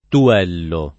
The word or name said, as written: tuello